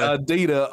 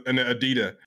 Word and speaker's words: Adidas: No, you gotta say it right. Adidas. – He said Adidas. Oh my god, bro. – He didn't even add the S to that motherfucker.